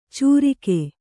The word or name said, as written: ಚೂರಿಕೆ